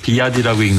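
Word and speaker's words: BYD: 아까 말씀드린 그 BYD라고 써있던 BYD라고 읽는다면서요.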